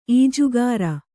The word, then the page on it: ಈಜುಗಾರ: ījugāra